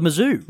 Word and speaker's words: Mizzou: The Mizzou. – The Mizzou. It's like Kalamazoo, so I should remember that because that's a place.